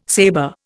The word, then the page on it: saber: Indian